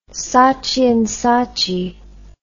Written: saatchi and saatchi

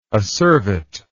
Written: acervate

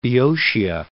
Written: Boeotia

